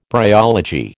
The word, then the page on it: bryology: American English